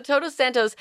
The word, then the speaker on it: Todos Santos: It's a coastal town in Mexico called Todos Santos. Am I saying that right? – You are. Your name means queen in Spanish, of course. – So I've heard. I love that. So Todos Santos, it's at the southern tip of the Baja Peninsula, views of the Pacific Ocean.